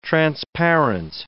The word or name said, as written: transparent